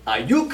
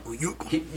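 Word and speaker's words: Aiyuk: I'm going to quickly say Brandon Ayuk. You got to say his name like that from now on. – Ayuk.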